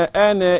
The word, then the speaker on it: أنا: انتبه على الألف اللي بعد النون، الدائرة اللي على، تقول من فضلك يا قارئ القرآن هذه الألف لا تنطق في الوصل، عند الوقف أقول وما أنا، إنما في الوصل لا تنطق.